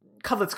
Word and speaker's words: Cudlitz: Cudlitz, yeah.